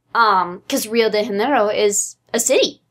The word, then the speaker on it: Janeiro: You don't pronounce the J, right? It's super soft. – It's Janeiro.